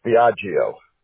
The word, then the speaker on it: Biagio: G-I-O. Okay. It's Biagio. – Okay. – B-I-A-G-I-O. – Okay, so B-A-I-G-I-O? – No, B-I-A. – B-I-A-G-I-O, okay.